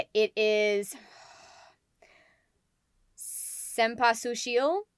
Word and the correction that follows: Cempasúchil: No, Cempasúchil. There we go. The accent is on the U.